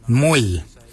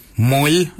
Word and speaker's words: mooly: Warrigal is spelled W-O-R-R-A-G-O-L. Warrigal. Warrigal is your upper mouth. For your nose, we say Moil. Moil is your nose. Moil is spelled M-O-O-L-Y.